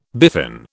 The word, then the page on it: biffin: British English